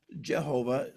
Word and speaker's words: Jehovah: They fell face down on the ground and cried out, "The Lord," capital L, capital O, capital R, capital D, that's Yahweh, Jehovah, Jehovah. Some people pronounce it, although there's no J sound in the Hebrew alphabet.